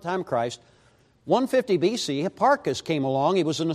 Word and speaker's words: Hipparchus: Hipparchus, he sounds almost like hippopotamus kind of, and that's kind of what I think of anyway.